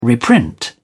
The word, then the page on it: reprint: British English